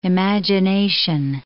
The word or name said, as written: imagination